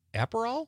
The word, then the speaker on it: Aperol: Liz from Sun Prairie says she's going to have tequila and soda with a lime or a splash of Aperol.